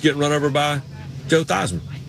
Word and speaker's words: Theismann: The way you pronounce his name is Theismann, but but they wanted to do a Heisman campaign for him at Notre Dame, and he became Theismann. And then that's just, at that point, you just quit correcting people.